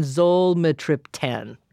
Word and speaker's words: zolmitriptan: Rizatriptan, and then zolmitriptan. Did I say that? – That's right.